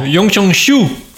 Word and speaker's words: Yongchong Xiu: Euh, non, Yongchong Xiu. Parce que je, en tant que représentant euh, français de la marque Xiaomi, je ne peux pas vous permettre de prononcer euh, ainsi le, X.